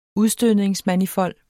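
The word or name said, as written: udstødningsmanifold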